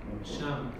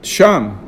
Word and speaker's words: Sham: Sham. – Sham.